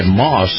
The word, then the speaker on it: Maus: Now, this portion of the program brought to you by Moss, that's spelled M-A-U-S, Moss Acura.